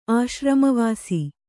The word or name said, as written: ಆಶ್ರಮವಾಸಿ